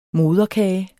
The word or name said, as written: moderkage